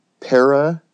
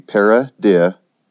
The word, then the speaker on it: paradisiacal: Oh, and by the way, that word, paradisiacal, is how you pronounce that word. So see, paradisiacal.